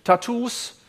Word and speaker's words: Tattoos: Gut, also Tattoos mit in dem Fall Doppel-T und Doppel-O, das dann eben als U ausgesprochen wird.